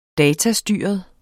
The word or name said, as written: datastyret